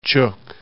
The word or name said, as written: Chuuk